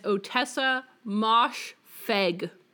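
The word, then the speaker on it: Ottessa Moshfegh: She told us that you pronounce the author of My Year of Rest and Relaxation and Eileen as Otessa Mosh Feg.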